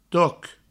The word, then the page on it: toc: to